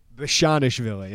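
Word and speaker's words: Bezhanishvili: So something that, you know, intrigued me last season, I think Kofi is probably part of the reason, but Georgie Bianishvili, did I pronounce that right? I'm going to be really happy if I did. Bianishvili? – Bishanishvili, yeah.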